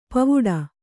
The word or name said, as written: ಪೋವಿಡು